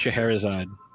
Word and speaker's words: Scheherazade: And for you to have a character named Scheherazade, which obviously I'm pronouncing it much different than you are. – No, you're doing great.